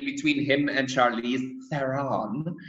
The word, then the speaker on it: Theron: I mean, in South Africa, people say Charlize Theron, because that's how we pronounce Theron. – Theron.